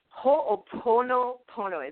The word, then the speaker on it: Ho'oponopono: Ho'oponopono, it's spelled H-O'O-P-O-N-O. So lots of P's and lots of O's.